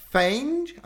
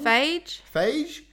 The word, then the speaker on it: Feige: Marvel Studios president Kevin Feige, I'm saying that wrong? – Feige, dropped the bombshell in an interview to the Toronto Sun.